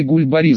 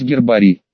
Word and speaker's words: гербарий: Один мой товарищ по работе говорил: после смены с дочкой в лес пойдем, ей в школе гульбарий задали собрать. Разумеется, мы всем дружным коллективом пытались наставлять его на путь истинный в плане грамотности, мол, правильно говорить гербарий, а тот заладил гульбарий, да гульбарий, а еще Массандра вместо мансарды.